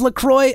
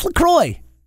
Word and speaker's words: LaCroix: No, well, apparently it actually is LaCroix. Apparently it rhymes with enjoy. That's their slogan, which she told me, and I didn't know. It's LaCroix.